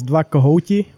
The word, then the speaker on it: Dva Kohouti: It's almost perfect. It's Dva Kohouti, but the Czech language and Czech pronunciation is pretty hard.